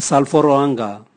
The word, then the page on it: salvoroanga: Raga